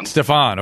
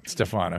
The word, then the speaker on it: Stephon: Well, no, my name was named after my grandfather, Stephen, but it's pronounced Stephon. – Stephon. Okay, excellent. Excellent. Stephon.